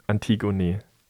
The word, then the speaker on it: Antigone: Auf Deutsch ausgesprochen, Antigone.